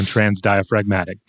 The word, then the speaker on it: transdiaphragmatic: Webster says use the hard G on transdiaphragmatic.